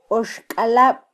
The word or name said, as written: oxkalab